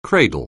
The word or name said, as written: cradle